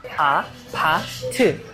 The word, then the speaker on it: APT: A, pa, t. U, u, u, u. It looks like you need to pronounce ew, ew. No. You should pronounce it like u, u. So it's a, pa, t.